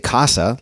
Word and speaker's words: Kasa: They have a line called, I think you say Casa, like Mi Casa es su Casa, but it's spelled with a K, K-A-S-A.